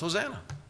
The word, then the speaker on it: Hosanna: You read the word Hosanna in the English, and it's pronounced Hosanna.